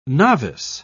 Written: novice

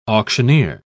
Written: auctioneer

